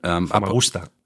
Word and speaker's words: Famagusta: Famagusta.